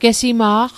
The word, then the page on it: gesima'q: Mi'gmaq